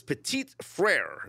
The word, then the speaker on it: Petit-Frere: And Titans offensive tackle Nicholas Petit-Frere.